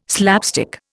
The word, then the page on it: slapstick: Indian